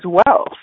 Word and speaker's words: Weal: And I do hope it is a contribution to you as well. So we do say the name a little weird here. It's Living Well, even though it's spelled W-E-A-L.